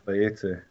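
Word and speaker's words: Vayetze (ויצא): Is that right? Have I pronounced that correctly? – Vayetze.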